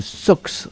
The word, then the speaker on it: Sox: C'est à la salle municipale de Sox. Sox, ça s'écrit S-O-X c'est X, bien sûr.